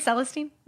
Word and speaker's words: Celestine: In this thrilling sequel, Celestine North must Did I say her name right? – I think so, yeah. Celestine. – Mm-hmm. Celestine. It's those words you never say out loud. – Celestine, I think you were right the first time.